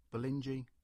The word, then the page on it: Bolingey: English